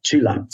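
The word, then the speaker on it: Csilla: It's really Chilia or Chila. I don't know how you pronounce. Say it again. Chila. Chila, mm.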